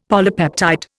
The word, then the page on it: polypeptide: Indian